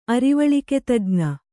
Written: ಅರಿವಳಿಕೆತಜ್ಞ

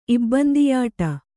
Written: ಇಬ್ಬಂದಿಯಾಟ

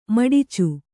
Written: ಮಿಡಚಿ

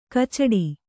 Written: ಕಚ್ಚಡ

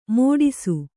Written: ಮೋಡಿಸು

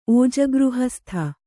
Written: ಓಜಗೃಹಸ್ಥ

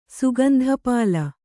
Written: ಸುಗಂಧಪಾಲ

